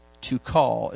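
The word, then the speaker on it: To call (טֻחָה): Now, this word I want to give you, it's tukal, and it's spelled TUWCHAH. TUWCHAH. To call is how it's pronounced, and it's 2910.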